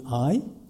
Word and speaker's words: Ai: My research partner is named Ai, spelled AI, like I, pronounced Asian, and meaning love in Japanese.